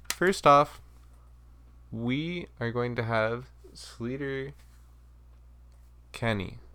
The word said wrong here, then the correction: Sleater: I always have a hard time pronouncing the name because it's spelled... Oh, Slater.